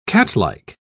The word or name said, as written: catlike